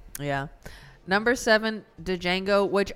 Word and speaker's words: Django: You remember the point in the movie where A, everybody calls him Django, and B, there's the racist hillbilly gun toter that calls him Django, and then he shoots him, and he's like, the D is silent, hillbilly, and then fucking kills him.